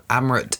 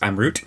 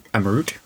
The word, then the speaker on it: Amrut: So, Amrut is a distillery. – Okay, first of all, it's pronounced Amrut. Amrut.